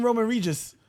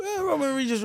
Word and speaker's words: Roman Reigns: What did I call him? Roman Regis. Roman Reigns, but it's spelled Roman Reigns.